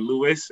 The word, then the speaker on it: Louis: So that's L-O-U-I-S for Louis or Louis, what I go by. And last name will be Masensi. So that's, bear with me, M-A-S-E-N-S-I.